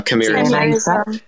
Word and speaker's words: chimerism: They will try to claim, there's another one claiming to have is it chimerism? Is that how you say it? Where they have an extra chimerism. – That's it.